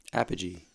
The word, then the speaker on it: Apogee: Apogee.